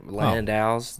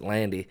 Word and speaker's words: Landau: Apparently, he said he doesn't know why his family pronounces it like that, but there are other Landaus that obviously pronounce it Landau. And for some reason, I don't know if it was a Texan thing or whatever, but his family pronounced it Landy.